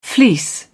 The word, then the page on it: fleece: British English